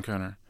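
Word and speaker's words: Kohner: Susan Conner.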